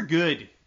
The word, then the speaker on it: Gudde: I will also say, just down the card, just to give a little shout out to my Northeastern folk, Trevor Good, his name is spelled G-U-D-D-E.